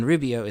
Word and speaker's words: Rubeo: The song is called Xenaxis, and Rubeo is spelled R U B E O.